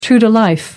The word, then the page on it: true to life: American English